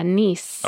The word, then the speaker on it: Nice: So the city in the south of France. How do you say that? – Uh, niece. Okay, just making sure that I didn't have that one wrong too.